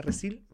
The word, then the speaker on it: Resille: Resille, así se escribe como como los nombres de Michelle, Giselle, bueno, Resil, se escribe Resille.